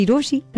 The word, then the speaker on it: Rochie: मेरा नाम है मिर्ची रोशी.